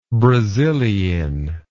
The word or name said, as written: brasilein